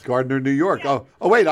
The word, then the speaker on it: Gardiner: Gardiner with a hard G. We're pronouncing it correctly.